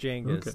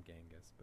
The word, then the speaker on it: Genghis: It is pronounced Genghis, technically. Everyone calls him Genghis, but it's Genghis.